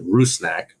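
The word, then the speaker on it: Rusnák: I saw the pronunciation guide that our guy James Wallard on Twitter, the PA announcer, got, said Rusnak. So we're going to try to go with that.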